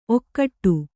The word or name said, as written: ಔಕಾಟ